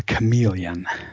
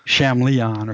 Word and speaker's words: chameleon: Which I mispronounced, I think. I pronounced it as it's phonetically spelled, which is like Shamleon or something like that.